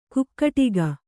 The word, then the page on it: ಕುಕ್ಕಟಿಗ: kukkaṭiga; kannada